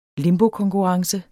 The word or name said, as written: limbokonkurrence